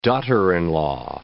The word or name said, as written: daughter-in-law